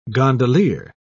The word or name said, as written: gondolier